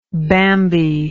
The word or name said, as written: Bambi